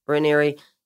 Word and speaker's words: Raniere: And also the other question to follow that up is why has Keith Raniere, is that how you say his name? – Raniere.